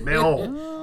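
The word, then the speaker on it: Mahón: I was going to say, the capital, is, I don't know how to pronounce it, Mahon? Mahon? – Mahon.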